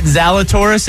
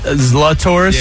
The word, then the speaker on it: Zalatoris: It's not Zalatoris, it's Zlatoris.